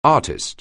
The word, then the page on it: artist: anglais